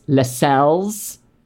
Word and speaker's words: Lascelles: Here I learned that the guy that I've been calling Lascelles, a.k.a. Tommy, his name is pronounced Lascelles.